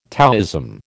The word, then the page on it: Taoism: American English